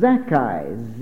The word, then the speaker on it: Zaccai: It's pronounced Zacchai. Z-A-C-C-A-I.